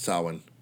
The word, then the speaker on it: Samhain: Even though it's spelled Samhain, but it's pronounced Samhain for some reason.